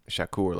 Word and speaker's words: Shakur: Shakur, like Tupac. – Yeah, yeah, Tupac.